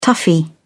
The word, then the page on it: toughie: British English